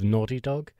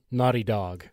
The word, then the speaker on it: Naughty Dog: I saw a kind of retrospective of 30 years of Naughty Dog, which, by the way, Naughty Dog sounds so much better said with an American accent. Naughty Dog. – Naughty Dog. – As opposed to Naughty Dog, because we like to pronounce every bit of the word.